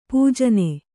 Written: ಪೂಜನೆ

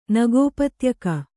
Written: ನಗೋಪತ್ಯಕ

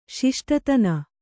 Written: ಶಿಷ್ಟತನ

